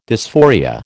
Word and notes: dysphoria: American English

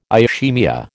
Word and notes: ischaemia: American English

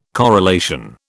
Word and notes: corelation: British English

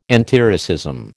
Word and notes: antiracism: American English

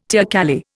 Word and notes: teocalli: Indian

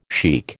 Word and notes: shaikh: American English

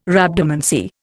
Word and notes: Rhabdomancy: Indian

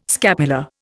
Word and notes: scapula: Indian

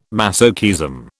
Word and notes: masochism: British English

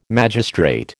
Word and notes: magistrate: American English

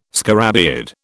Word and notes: scarabaeid: British English

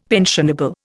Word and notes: pensionable: Indian

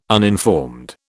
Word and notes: uninformed: British English

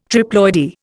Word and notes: triploidy: Indian

